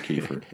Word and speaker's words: Kiefer: Two F's. First one silent. Was the second one silent? – So second one is silent. – Okay. – You can hear the first one. – Second F is silent in Kiefer. Okay. It's K I E F silent F E R.